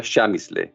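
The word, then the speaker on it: Przemyśl: Esta ciudad se llamaba y se llama Chamisle. Es una palabra polaca que no se parece nada a cómo se pronuncia y que se escribe de la siguiente manera: P-R-Z-E-M-Y-S-L. Se pronuncia Chamisle.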